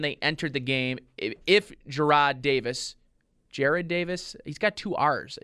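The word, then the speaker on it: Jarred: It's hard for me to pronounce his name. – Jared. – I want to say Jared. – Should be Jared. – Jared. – No, there's no red. – It's E-D. It's J-A-R-R-E-D. – Jared.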